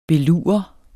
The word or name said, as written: belure